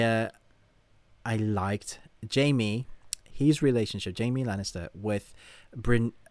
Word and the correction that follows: Brienne: Yeah, Brienne.